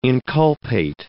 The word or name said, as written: inculpate